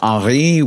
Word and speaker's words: Henri: It's a French spelling of the word Henry, H-E-N-R-I, so it's pronounced Henri, just FYI.